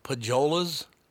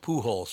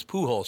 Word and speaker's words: Pujols: She goes, so, this guy that's in the on-deck circle, Pajolas, is it Pajolas? I said, no, it's pronounced Pujols. She goes, what? I said, his name is Pujols.